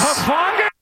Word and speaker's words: Hufanga: It was Hufanga.